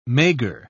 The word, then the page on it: maigre: British English